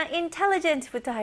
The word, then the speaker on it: intelligent: 첫 번째 단어 intelligent부터 할게요. 이거는 총명한이라는 뜻을 가지고 있고요. 발음은 intelligent. intelligent. 강세가 어디에 들어가죠? 네, 맞아요. tell에 들어가죠. intelligent.